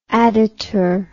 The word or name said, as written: additur